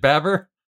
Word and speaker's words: Babber: So he's like, wait, is it spelled just like Babar, but you have to know that you pronounce it Babber? – I think it's B-A-B-B-E-R.